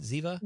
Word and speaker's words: Ziva: So we'll get Lisa's opinion on the new NCIS spinoff, Tony and Ziva. Am I saying that right? Ziva? – You are saying that right.